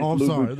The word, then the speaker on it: Louboutin: You got to pronounce it with the French Louboutin.